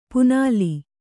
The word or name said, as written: ಪಣ್ಣೆಲೆ